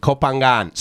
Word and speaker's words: Koh Phangan: Koh Phangan. So you do pronounce the G. It's like a soft G, Koh Phangan.